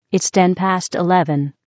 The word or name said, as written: 10 past 11